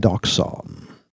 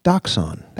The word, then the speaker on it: Daxane: I am Doxon. – Yes, spelled D-A-X-A-N-E, but it's pronounced Doxon. That's how your mother intended.